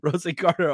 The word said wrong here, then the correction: Rosengarten: only if you say the Rookie of the Year movie, man. Is that not right? I think I'm saying it right. Am I saying it wrong? Now, that is kind of my thing, is saying guys' names wrong. I don't do it out of disrespect. I do it out of ignorance. Rosengarten. Yeah, Rosengarten. That's the way it's spelled.